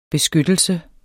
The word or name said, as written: beskyttelse